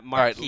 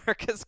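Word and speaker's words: Marques: Marcus Colston is how you say the name.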